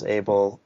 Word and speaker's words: Abele: His name is actually Abel. And I used to say it as that when I first saw it as well, because there are African names with that spelling and that pronunciation, but it's Abel.